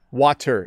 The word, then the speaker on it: water: Water is not how we pronounce that word. Even though there is a T in the middle, we say water. Water. You hear how I'm over-pronouncing it a bit to emphasize. But, I'm thirsty. I'm going to drink some water. Okay? It's just water. It's like a softening of the T sound.